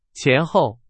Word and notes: 前后: Chinese

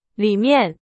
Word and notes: 里面: Chinese

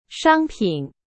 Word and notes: 商品: Chinese

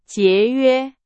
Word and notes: 节约: Chinese

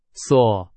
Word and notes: 所: Chinese

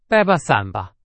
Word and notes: 伞: Chinese

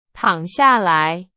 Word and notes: 躺: Chinese

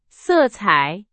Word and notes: 色彩: Chinese

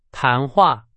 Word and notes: 谈话: Chinese

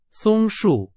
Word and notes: 松树: Chinese

